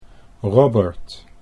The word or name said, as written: Robert